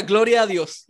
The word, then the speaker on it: Gloria a Dios: Did I say it right? Yeah, yeah, yeah. Gloria a Dios. Yeah, you say it. You say it in Spanish.